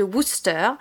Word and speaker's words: Worcester: Ah, bien prononcé. Ouais, ouais, ouais. tu bah la prononces très bien. Et oui, on m'a appris là-bas parce que euh, j'ai appris à mes dépens dès le premier jour hein, que ça ne se prononçait pas Worcester,